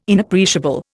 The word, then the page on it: Inappreciable: Indian